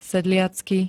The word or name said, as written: sedliacky